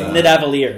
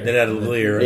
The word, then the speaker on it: Nidavellir: It's Nidavellir. – Nidavellir.